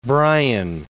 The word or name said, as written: Brian